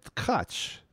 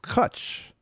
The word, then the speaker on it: Tkatsch: It's spelled T-K-A-T-S-C-H. And I've always said Tkach, Tkach. Now, I'm reading this from the liner, the little booklet that came with the CD, Israel Leib, and it says, rhymes with hutch. So it sounds like it's pronounced kutch.